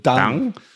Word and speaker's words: Deng: At some point, Mao gets past it, and you say Deng Xiaoping? – Well, I pronounce it Deng.